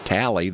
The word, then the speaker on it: Talley: It rhymes with alley. They were in the alley, and he's Talley.